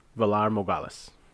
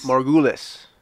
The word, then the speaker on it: Valar Morghulis: Valar Morghulis. – No. You're already wrong. God damn it. Valar, we Googled this. – Morghulis.